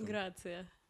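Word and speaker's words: Grazia: Ну, «Грязь» или как он читается? Ну, я не шарю. – Что? – Ну, есть журнал «Гразия» на английском. – «Грация». – «Грация»? Ну, «Грязь», короче. Но в журнале «Грязь» с заголовком «Влад Топалов наконец защитил Ирину Тодоренко от нападок».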